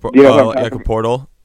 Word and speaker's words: Poeltl: Jakob Poeltl.